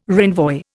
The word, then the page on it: renvoi: Indian